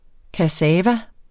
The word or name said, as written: kassava